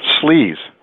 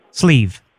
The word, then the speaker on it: Sleeve: My name is Sleeve. – Sleeves. – Sleeve. It's the Anglo version of my full name. – Spell that. – Shlevnovic Migdyklevanovich. But you may pronounce it Sleeve.